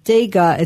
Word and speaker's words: Tega: And it's Daga. It's pronounced like with a D, but it's spelt with a T. – Yeah. – So it's Tega, but it's pronounced Daga.